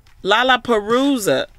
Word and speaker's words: Lollapalooza: Lollapalooza.